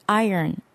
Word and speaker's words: Iron: Iron. – 철, 다리미. Iron이라고 하는데요. Iron을 발음해 조심해야 되겠습니다. Iron이라고 읽으면 안 됩니다. R을 묵음에 가깝게 생각하는 게 좋겠습니다.